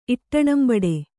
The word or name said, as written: ಇಟ್ಟಣಂಬಡೆ